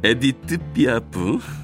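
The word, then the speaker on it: Édith Piaf: 에드뜻 삐아 아니 발음이 잘안 나는 좀 뭐, 비슷하게 해보려고 그랬는데 에디트 삐아프 라빙 로즈 장밋빛 인생입니다.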